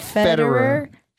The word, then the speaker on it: Federer: Federer.